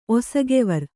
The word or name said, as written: ಒಸಗೆವರ್